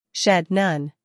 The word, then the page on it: shed: ʃed